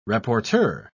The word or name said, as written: rapporteur